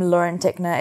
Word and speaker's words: Lauren Tickner: So it's just my name, Lauren Tickner. It's spelled L-A-U-R-E-N. And then my surname, T-I-C-K-N-E-R.